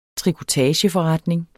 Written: trikotageforretning

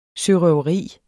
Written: sørøveri

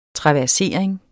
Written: traversering

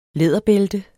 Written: læderbælte

